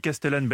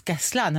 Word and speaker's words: Castellane: Ça s'écrit Castellane. – Ah oui, mais... – moi j'ai dit Castellane bêtement. – Et non, ça se prononce Castellane. Alors si vous dites Castellane, là vous passez pour un.